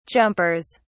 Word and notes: jumpers: British English

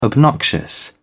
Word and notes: obnoxious: British English

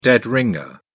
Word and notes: dead ringer: British English

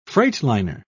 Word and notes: freightliner: British English

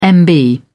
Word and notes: mb: British English

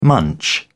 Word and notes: munch: British English

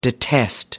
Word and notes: detest: American English

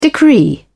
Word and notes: decree: British English

